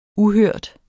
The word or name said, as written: uhørt